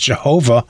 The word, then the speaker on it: Yahweh: A few weeks ago, you told me that the Bible does that to indicate the original Hebrew actual name of God, sometimes pronounced Jehovah, although probably the Jews would have called him Yahweh.